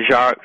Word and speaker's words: Jacques: Okay, Jack Fresco. Is that how you pronounce his first name? – Yeah, Jacques Fresco. Yeah. – Jacques Fresco, sorry.